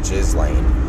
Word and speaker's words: Ghislaine: And that's probably how you actually pronounce her name, is Ghislaine.